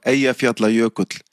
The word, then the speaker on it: Eyjafjallajökull: Also wir haben ihn, glaube ich, immer ausgesprochen mit Eyjafjallajökull. Aber jetzt möchte ich es einmal in Isländisch korrekt hören. Wie hieß dieser Vulkan? – Ja, es ist ein ganz einfacher Name. Es heißt einfach Eyjafjallajökull.